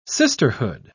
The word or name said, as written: sisterhood